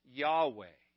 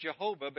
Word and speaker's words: Yahweh: We know for certain it was not pronounced Jehovah because there was no J sound in the Hebrew language. It was a Y sound, not a J.